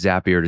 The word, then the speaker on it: Zapier: That's the reason why Zapier rhymes with happier. Bet you didn't know that. Now you know how to say it the right way, too.